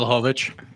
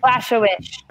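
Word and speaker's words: Błachowicz: It's Adesanya and Blakowicz? How do I say his name? Mm-hmm. – Blachowicz. – Blachowicz.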